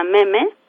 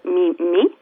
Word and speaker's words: Meme: la curaduría de una exposición que se llama Meme, Pronounce Meme, Not Me, Me. Es también como un juego de palabras, se, se pronuncia Meme, No Me, Me.